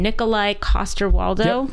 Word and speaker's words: Nikolai Coster-Waldau: Well, this was, I can't say his name, Nikolai Coster-Waldau. – Yep. – Did I say that right? Yes.